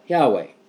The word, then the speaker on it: Yahweh: Those consonants, yod, he, wa, and he, when pronounced together, sound like Yahweh.